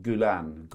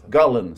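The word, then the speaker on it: Gullane: Vill jag bara påminna om Scottish Open på, hur uttalar man? Gullen. Gullen, så uttalar man det.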